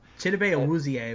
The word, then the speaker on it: Chidobe Awuzie: I think he and Adoobie will just be a great tandem in Dallas. I know I mispronounced that. Leave me alone. – Chidobe Awuzie, I believe, is his name.